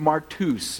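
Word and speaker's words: martus (μάρτυς): It's pronounced martus. It's where we get our word martyr.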